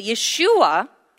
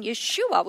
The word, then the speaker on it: Yeshua: but Yeshua, in the original pronunciation, Yeshua, which means the Savior, in that name, all souls of men, souls of all nations will pray.